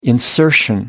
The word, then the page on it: insertion: American English